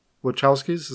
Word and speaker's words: Wachowskis: And they're banking off the Wachowskis, is how you say their name, because they did The Matrix before that.